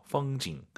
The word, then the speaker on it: 风景: 풍경 올라간 순간 엄밀히 말씀드리면 틀린 발음이에요. 뒤에가 사성이잖아요. 반삼성이죠. 펑징 끝나야 됩니다. 풍경이 중국어로 펑징. – 펑징. 꼭 기억하시기 바랍니다.